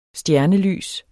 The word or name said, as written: stjernelys